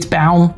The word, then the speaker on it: Baum: Do you say Baum? – So, yeah, I mean, technically it's Baum. It means tree in German, which is, you know, what I was told.